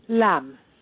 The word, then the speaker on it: Lam: And how do you say your last name? Liam? – Lam. – Lam. Lam. Okay, there was like a little lam.